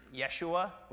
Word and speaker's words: Jeshua: Then there's this high priest, and they pronounce him, they spell it J-E-S-H-U-A. It's pronounced Yeshua, which is Joshua.